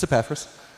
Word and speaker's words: Epaphras: It's Epaphras, chapter 4.